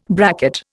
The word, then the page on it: bracket: Indian